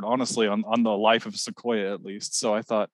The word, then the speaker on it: Ssiquoya: And of course, Sequoia spelled his name S-S-I-Q-U-O-Y-A. So it's S-S-I-Q-U-O-Y-A, Sequoia. It's four syllables instead of three syllables.